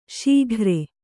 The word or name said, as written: ಶಿಖರಿ